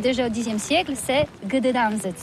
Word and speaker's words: Gdańsk: Oui, c'est pas très facile pour des étrangers, mais remarque bien que la langue polonaise, c'est la langue où on prononce chaque lettre qu'on écrit. C'est pourquoi on dit Gdańsk.